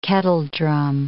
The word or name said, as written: kettledrum